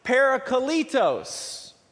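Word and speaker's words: parakletos (παράκλητος): Now, if you look up that word comforter in the Greek to find out its original translation, the word is actually pronounced parakletos.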